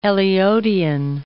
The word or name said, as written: Eliotian